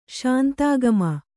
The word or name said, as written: ಶಾಂತಾಗಮ